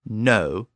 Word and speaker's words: know: And English later just dropped that initial K sound altogether, and it became no, K-N-O-W, but pronounced with the N at the beginning. Well, Latin did the same thing with the G-N-O root when it appeared at the beginning of a word.